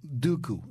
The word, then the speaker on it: Dooku: How do you correctly pronounce Naboo and Dooku? It's a good question. – It's Naboo. – Naboo. – It's Naboo, and it's Dooku.